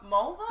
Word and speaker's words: Mulva: Mulva? It rhymes with female anatomy.